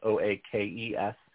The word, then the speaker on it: Oakes: There is a silent E in my last name, so it's OAKES.